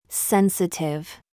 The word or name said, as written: VERVE